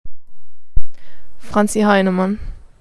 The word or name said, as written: Franzi Heinemann